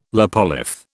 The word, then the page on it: lopolith: British English